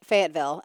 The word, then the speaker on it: Fayetteville: Is that how you say it? Fayetteville? – That is how you say it, Fayetteville.